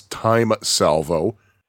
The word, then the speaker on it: T:ME Salvo: And by the way, I did have a question as to how the game is pronounced, because it is spelled T:ME Salvo. I was like, is it T:ME? Is it T:ME? Is it what? But I have heard from Mike himself that it is Time Salvo,